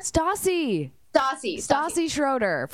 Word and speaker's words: Stassi Schroeder: Is it Stassi Schroeder? – Stassi Schroeder from Vanderpump Rules.